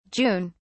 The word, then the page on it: June: British English